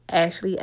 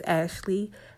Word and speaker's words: Ashlea: My name on Instagram is Ashley S. Perkins. And I spell it A-S-H-L-E-A. My middle initial is S, last name Perkins, P-E-R-K-I-N-S. So once again, it's Ashley S.